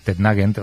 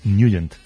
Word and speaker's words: Nugent: Hombre, siempre lo hemos llamado en el barrio Tednagent o Tednagent. – Claro, ¿no? Yo era de Tednagent. – Pero la pronunciación correcta es Nugent.